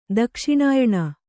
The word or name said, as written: ದಕ್ಷಿಣಾಯಣ